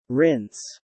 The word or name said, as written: Rinse